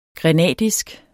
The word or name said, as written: grenadisk